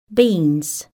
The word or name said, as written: beans